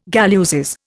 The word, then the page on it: galluses: Indian